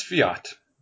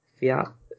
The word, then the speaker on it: Fiat: Is that not pronounced Fiat? – I believe it's Fiat. – Fiat? Fiat. – And so Google. La Fiat together is almost like Lafayette, and I was like, ah, very cool. I see what you did. – Pronunciation. Yeah, it says Fiat or Fiat, so I guess either.